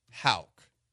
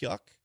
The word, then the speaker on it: Houck: Number 1, Tanner Houck. It's not pronounced Yuck.